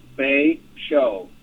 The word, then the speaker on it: Betio: So the island... Bay Show, B-E-T-I-O, is pronounced Bay Show, B-A-Y hyphen S-H-O.